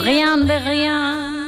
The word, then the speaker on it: Rien de rien: Rien de rien.